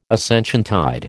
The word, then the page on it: Ascensiontide: American English